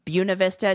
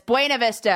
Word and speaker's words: Buena Vista: They all call it Buena Vista. It's Buena Vista.